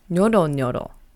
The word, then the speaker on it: nyoronyoro: Now the second word is nyoronyoro. Note how there is a Y between the N and the O. Now this one is a little hard to pronounce, so let's do it again. Nyoro nyoro.